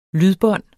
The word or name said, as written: lydbånd